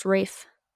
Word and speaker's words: Ralph: It's spelled like Ralph. Rafe Fiennes, he plays Voldemort. – Yes. – What do you mean spelled like Ralph? – It's spelled like Ralph, but it's pronounced Rafe. R-A-L-P-H. F-I-E-N-N-E-S.